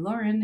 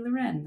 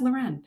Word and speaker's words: Lauren: And yes, my name is not Kaylee Loren, it is Kaylee Loren. That is what my mother did to my middle name, even though it's spelled Lauren. So it's pronounced Loren.